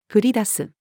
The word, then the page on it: 降り出す: female